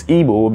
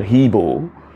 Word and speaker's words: Igbo: They couldn't pronounce it. They called Hebo.